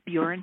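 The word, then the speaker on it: Burin: I am from a little town on the Buren Peninsula, like it rhymes with urine, it's not bird, on the Buren Peninsula called Mortier.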